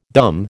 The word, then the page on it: dumb: American English